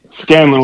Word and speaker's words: Scanlon: Scanlon with an N.